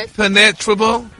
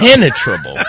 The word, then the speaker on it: impenetrable: Impenetrable. Huh? Impenetrable. – No, your emphasis Rock. Is on the wrong syllable. – They were so dumb, they had face masks. Had a chin strap. – Impenetrable.